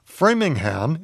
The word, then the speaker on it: Framingham: But Framingham is pronounced framing, as in one framing a picture, and ham, as in a Virginia ham.